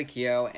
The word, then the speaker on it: Keough: Riley Keough,